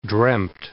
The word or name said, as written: dreamt